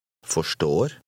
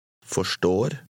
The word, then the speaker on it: forstår: Now repeat this word after the speaker, part by part. – Står. Står. For. For. Forstår. Forstår. – Say, understand. – Forstår. – Pay special attention to the end of each syllable. Repeat after the speaker, trying to match his pronunciation.